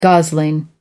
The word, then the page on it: gosling: American English